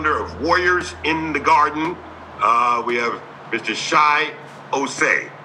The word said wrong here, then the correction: Chi Ossé: Did I pronounce that correctly? – Is he on? It's Chi Osei.